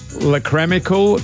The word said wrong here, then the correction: lacrimal: carungles. – That is not how you say it, I'm sure. – Carunkle. – What? – A lacrimal carunkle.